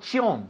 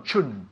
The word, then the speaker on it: suggestion: Not chion, no, chun, chun. Suggestion.